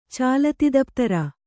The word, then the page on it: ಚಾಲತಿದಪ್ತರ: kannada